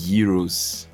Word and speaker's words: gyros: They're pronounced gyros.